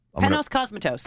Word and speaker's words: Panos Cosmatos: Panos Cosmatos.